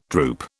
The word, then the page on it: droop: British English